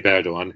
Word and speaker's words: Erdogan: Well, can you sort of talk about Erdogan and sort of what role he's had in the change? – Well, you did pronounce it reasonably correctly. Recep Tayyip Erdogan,